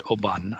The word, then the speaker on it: Oban: Yeah, so in duty free, they always pronounce it Oban. I know that because I asked somebody, is this the correct pronunciation? And they said, yeah, it's Oban.